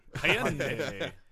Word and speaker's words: Hyundai: The Hyundai Sun Bowl. That's the Detroit pronunciation. – Hyundai.